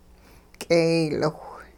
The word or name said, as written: k'eeyliwh